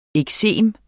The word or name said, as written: eksem